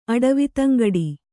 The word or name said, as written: ಅಡವಿತಂಗಡಿ